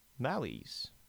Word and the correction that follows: Mallee: Am I saying that right? – I have no idea what word you're even trying to say. – A Mallee. – Mallee. – Mallee. – Cool. – Mallee. M-A-L-L-E-E.